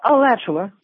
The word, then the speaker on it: Alachua: Alachua, am I saying that right, Florida? – Yeah, Alachua.